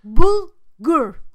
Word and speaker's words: bulgur: Yeah, booger. So a booger. – Not booger. Bulgur. – Booger. Just yes. – Because. You know, B-U-L. G-U-R. – So that sounds... That's spelled like burger, yeah? – No. B-U-L.